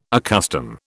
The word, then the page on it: accustom: British English